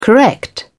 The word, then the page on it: correct: British English